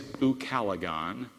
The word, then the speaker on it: ucalegon: It is ucalegon. It's spelled U-C-A-L-E-G-O-N, ucalegon.